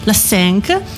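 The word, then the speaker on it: La Cinq: La Senc, ho detto bene? – Sì, sì. – La Senc, mentre in Belgio, in Belgio è arrivata nel 97 su Club RTL.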